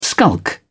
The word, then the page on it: skulk: British English